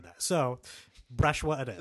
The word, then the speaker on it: Brzechwa: Oh, Danny, how would I pronounce the name Brzechwa? B-R-Z-E-C-H-W-A? – Brzechwa. – Okay, just sneeze a little. – Brzechwa really is what I'm going to say.